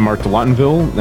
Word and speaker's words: Mark Delottinville: And I'm Mark Delottinville, and I'm the producer behind Cruise.